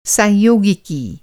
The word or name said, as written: सांयोगिकी